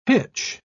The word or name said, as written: hitch